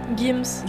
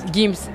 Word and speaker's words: Gims: Gims. – Gims.